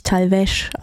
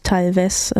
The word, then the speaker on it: Talvez: Natürlich Talvez ausgesprochen, nicht Talvez, wie ich es eben anmoderiert habe.